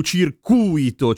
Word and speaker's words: circuito: Il circuito è quello elettrico oppure la pista e ha l'accento sulla U, circuito.